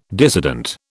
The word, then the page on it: dissident: British English